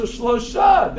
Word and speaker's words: Shalosh: Is Shalosh really how you say the number three? You're like, yeah, Shalosh is how you say it. But there's also Shlosha.